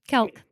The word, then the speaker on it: Kelk: Is that how I say your last name? – Yes, it is. Just Kelk. Good. Just say it how you see it.